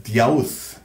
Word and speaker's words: Zeus: It's actually pronounced as Zeus in the Greek language. Zeus. Zeus is an anglicized pronunciation.